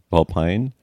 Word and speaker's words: vulpine: is a hard line over the vowel, is that a hard I, vulpine? – Yes. Long I, yes.